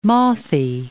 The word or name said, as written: mothy